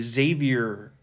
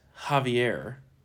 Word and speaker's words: Javier: I like to say Xavier Becerra. – Yeah. – But apparently it's Javier.